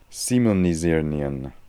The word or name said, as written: Similnisirnian